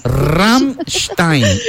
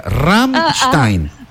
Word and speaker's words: Rammstein: Rammstein. – ¡Ah! Mira. – Se dice diferente. – Me llega. No, mire, se dice Rammstein. A ver, repita. Rammstein.